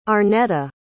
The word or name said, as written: Arnetta